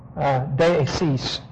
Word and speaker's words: deesis: It's the word deesis. Deesis means to make specific requests. D-E-E, long E, the second one, S-I-S.